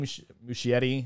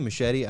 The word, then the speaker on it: Muschietti: I say Muschietti. I think Pat puts a weird emphasis on the I. – The Muschietti. – Muschietti. – Muschietti. – No, it's more American. He says Muschietti.